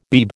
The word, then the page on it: Beeb: American English